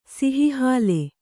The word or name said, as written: ಸಿಹಿಹಾಲೆ